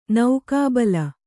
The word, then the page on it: ನಾಗಬಲಿ: nāga bali